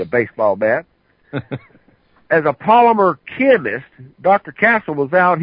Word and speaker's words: polymer: He's been out here for a number of years as a Palomar, and Dr. Castle, please forgive me if I mispronounce that, as a Palomar chemist. – A polymer chemist.